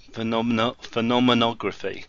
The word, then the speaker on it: phenomenography: and hopefully I can pronounce this correctly, phenomenography. Is that correct? Yep, good.